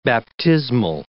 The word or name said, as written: baptismal